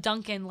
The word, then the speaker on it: Duncan: So I always used to say when I tell people what my name or how to spell it, I'm Duncan like Tim, not like donuts.